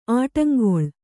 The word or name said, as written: ಆಡುಂಗುಳಿ